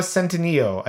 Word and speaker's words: Centineo: Next one up is Noah Centineo. I think it's the name right. Centineo is...